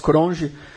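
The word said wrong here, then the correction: Cronjé: Cronier. Cronier, desculpa, é que eu falo com a pronúncia do sul.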